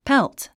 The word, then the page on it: pelt: pélt; ペルト